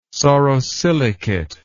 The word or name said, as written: sorosilicate